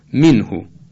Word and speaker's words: منه: مثلا منهو، بگیم منهو، منهو، نه این درست نیست، منهو، منهو. تلفظ دقیق عربی این کلمه که در قرآن هم طبیعتاً همین رعایت میشه در هنگام تلاوت های صحیح، همینطورست که عرض میکنم، منهو.